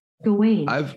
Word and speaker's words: Gawain: Oh, it's G-A-W-A-I-N, that one? – Yeah. – I'm looking up the fucking pronunciation. – Garwin. – You might be right. – Hurry on, Garwin. – Garmin. – Fucking hell. – I'm rolling joint number 3 now. – Respect. – I'm going to be some blueberry muffin from Sibility. Uh-huh. Thank you. – Gawain. – It says Gawain.